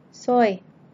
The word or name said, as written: soy